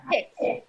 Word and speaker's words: PYX: Tell me what the hope PYX Global is, and is there how do you pronounce that PYX? – It's pronounced PIX, just like pixel PIX.